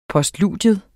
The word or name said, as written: POSTLUDIER